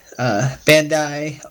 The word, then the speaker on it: Bandai: Yeah, some people call it Bandai. Other people call it Bandai. So okay. I mean, it's like tomato tomato. So I mean, it just depends on how people. A lot of people pronounce it each way.